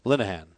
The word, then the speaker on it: Linehan: especially with the addition of Scott Linehan, how do you pronounce it? – Linehan.